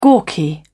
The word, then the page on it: gawky: British English